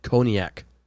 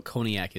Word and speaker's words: cognac: And cognac. – I don't even know what cognac is. – I think it's pronounced cognac. – Cognac. Yeah, you're right.